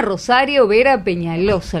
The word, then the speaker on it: Rosario Vera Peñalosa: Y se llama Rosario Vera Peñalosa. ¿Lo dije bien? – Sí.